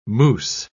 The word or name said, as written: mousse